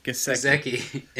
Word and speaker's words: Gesicki: Gesicki in the first round.